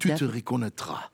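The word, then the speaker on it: Tu te reconnaîtras: Uh, uh, tu te reconnaîtra. – Ja, dat spreek je mooi uit. Tu te reconnaîtra.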